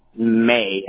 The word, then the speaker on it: May: May, M-A-Y. – M-A-Y, perfect.